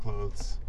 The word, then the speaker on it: clothes: Do you pronounce the TH or do you say clothes? Like close the door, kind of.